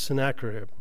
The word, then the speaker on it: Sennacherib: Sennacherib. I'm trying. He was a king, Sennacherib. That's what it is, Sennacherib.